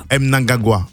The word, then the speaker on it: Mnangagwa: Le portrait, aujourd'hui portrait consacré donc à Emmerson Mnangagwa. C'est bien cela, je prononce comme ça Mnangagwa. – Mm-hmm. M, c'est pas N. – C'est M. Oh non, c'est Mnangagwa.